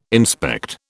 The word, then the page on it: inspect: British English